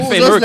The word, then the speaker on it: fémur: C'est la fémur, Chris, le fémur, l'os de la cuisse.